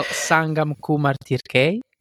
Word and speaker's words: Sangam Kumar Tirkey: So your name was or is, I don't know if I can pronounce very well, Sangam Kumar Tirke.